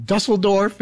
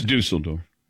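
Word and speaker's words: Düsseldorf: Hansi Nod, if you're pronouncing it right, later turned onto coaching in Dusseldorf and had several stages with the German Basketball Federation. – It's Dusseldorf. – What I call it, Dusseldorf? – Yeah. – Dusseldorf.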